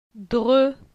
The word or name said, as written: Dre